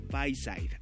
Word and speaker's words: Bayside: No sé cómo por ahí lo lleguen a pronunciar, pero es Bayside.